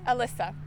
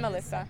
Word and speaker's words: Alyssa: Alyssa. Hi, I'm Alyssa.